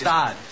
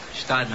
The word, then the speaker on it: Gstaad: Gstaad. The G is silent. Gstaad. – Gstaad. – Stad, man.